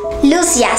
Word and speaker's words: Lucia: Lucia, sí, lo he pronunciado bien. Lucia es un nombre portugués.